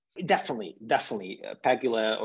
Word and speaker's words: Pegula: the women's matches Swiatek pegola or pegula that's how you pronounce